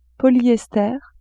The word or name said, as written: polyester